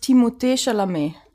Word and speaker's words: Timothée Chalamet: Also, wenn man wirklich Französisch das ausspricht, ist das Timothée Chalamet.